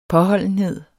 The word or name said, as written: påholdenhed